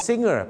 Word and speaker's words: singer: เขาจะไม่ได้ออกเสียงว่า singer นะครับหรือ singer นะครับมาจากเย็บผ้าแล้วครับเขาจะออกเสียงว่า singer เป็นงองูครับนี่แหละครับไม่มีกฎกติกามารยาทนะครับ singer ไม่ใช่ singer ครับ